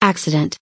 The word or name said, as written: accident